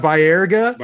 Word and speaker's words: Baerga: I couldn't think of his name, but I didn't want to mispronounce it. I knew Baerga. – You got Baerga.